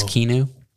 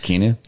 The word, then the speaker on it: Keanu: I believe it's Keanu. – Yeah, it's pronounced Keanu.